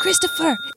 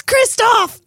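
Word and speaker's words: Kristofer: It's not Christopher. It's Kristoff.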